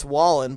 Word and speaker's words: Wallen: How do you say that? That cunt's name? Wallen? – I think it's Wallen, but I'm pretty sure it was Jason Aldean that did the, like, try that in a small town.